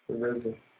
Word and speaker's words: RZA: The RZA.